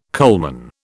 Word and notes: coalman: British English

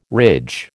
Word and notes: ridge: American English

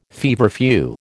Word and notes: feverfew: American English

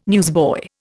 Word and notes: newsboy: Indian

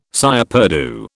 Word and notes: cire perdue: British English

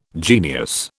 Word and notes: genius: British English

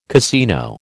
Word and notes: casino: American English

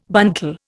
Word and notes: buntal: Indian

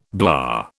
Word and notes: blah: British English

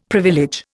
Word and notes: privilege: Indian

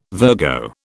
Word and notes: Virgo: British English

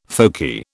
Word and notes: folky: British English

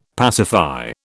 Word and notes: pacify: British English

